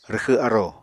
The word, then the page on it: rwxw arò: Apatani